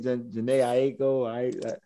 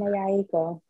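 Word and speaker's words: Jhené Aiko: I said it, Janae Aiko. – You said it right. – Big Sean girl. Yeah, there we go. Or Summer Walker. – Janae Aiko.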